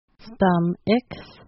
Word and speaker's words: stomachs: Sometimes the A-C-H-S is a little bit hard to pronounce, but you want to pronounce it as ix, stum-ix.